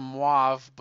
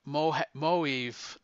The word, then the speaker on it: Moev: Oh, so I called them mauve, but I loved Moeve. That's what Andrew Peepwig told me. I said, "Who are you talking about, Moeve?" And he said, "That's how you pronounce it."